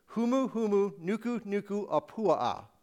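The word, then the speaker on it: Humu, humu, nuku, nuku, apua'a (humuhumunukunukuāpuaʻa): So I will teach you how to pronounce this word. Humu, humu, nuku, nuku, apua'a.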